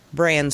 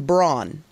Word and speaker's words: Bran: Is it Sam Tarly, Jon Snow, Bran Stark, or Hodor? And I have to ask, my husband calls him Braun. Have I been pronouncing it incorrectly all this time? – It's Bran.